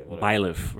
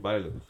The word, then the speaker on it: Byleth: I said his name wrong, but I don't care. – Okay, Byleth, right? – Byleth. – Yes.